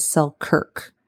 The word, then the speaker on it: Selkirk: I was talking about an individual and I kept calling him Selkin or something like that. But his name is Selkirk.